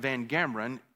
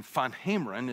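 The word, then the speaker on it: VanGemeren: I particularly remember Dr. Willem van, we pronounced it Van Gameren. It's a Dutch name. Van Hameren is the way you're supposed to say it. We just said good Southern Van Gameren.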